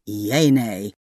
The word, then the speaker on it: Yeanay: That's when you need Yeanay. Y-E-A-N-A-Y, pronounced just like you spell it, is a simple app that lets you ask any yes or no question.